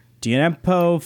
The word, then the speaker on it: Djenepo: Uh, they bring in Moussa Djenepo from Stanley The D is in, uh, silent. The D is silent. Have you not seen Django?